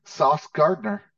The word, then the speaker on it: Sauce Gardner: Am I pronouncing his first name correctly? – Yeah. – He is the sauce. – Yeah. Sauce Gardner.